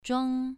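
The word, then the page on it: zhuang: tone 2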